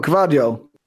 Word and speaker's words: Gvardiol: Oh, Gvardiol.